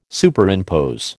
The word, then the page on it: Superimpose: American English